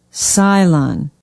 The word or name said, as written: Cylon